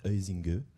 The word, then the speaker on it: Huizinga: C'est qu'a priori, la prononciation réelle de Johann Huizinga, c'est en fait Huizinga.